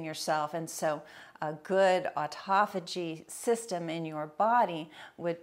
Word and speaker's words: autophagy: Uh, autophagy is how it's pronounced, but it's auto self phagy eat, eat self.